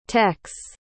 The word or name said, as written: techs